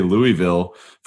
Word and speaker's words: Louisville: And actually, let me just back up a step because I say Louisville for folks who are not from Louisville. That's how you really pronounce it.